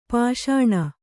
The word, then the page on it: ಪೋಷಣ: pōṣaṇa